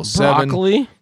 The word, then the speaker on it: Broccoli: Broccoli? – Yep. That's what it's spelled. B-R-O-C-C-O-L-I.